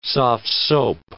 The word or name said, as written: soft-soap